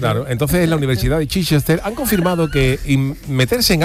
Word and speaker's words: Chichester: Eso sería, se pronunciará Chichester o algo así, ¿no? – Chichester, claro.